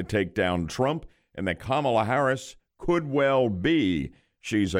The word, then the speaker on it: Kamala: but it really got rough when Kamala Kamala Harris.